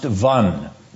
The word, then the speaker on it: Van: That is Lake Van, spelled V-A-N, pronounced Vun.